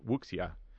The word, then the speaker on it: Wuxia: W-U-X-I-A, there's a gentleman who I think was pronouncing it like Wuxia. And, you know, the X, he was pronouncing it out.